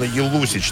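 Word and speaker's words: Jelusić: А в июле этого года White Snake объявили, что пригласили хорватского певца, мультиинструменталиста Дина Елусич, так правильно произносится его фамилия.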